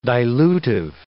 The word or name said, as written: dilutive